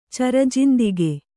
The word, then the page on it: ಚರಜಿಂದಿಗಿ: Kannada